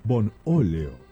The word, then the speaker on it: Bonóleo: Aceite Bonóleo, Bonóleo, con acento en la O.